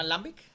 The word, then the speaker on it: Lambic: Eh, se llama Lambic, así como se escribe la, cerveza L-A-M-B-I-C.